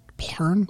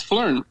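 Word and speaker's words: Phlearn: Is it Flurn or is it Plurn? Plurn? – We have had many different attempts at pronouncing it. It's Flurn. Yeah. It's like photography learn. – Gotcha. – Yeah, like learn.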